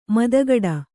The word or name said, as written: ಮತಿಗಿಡು